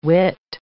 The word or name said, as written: whit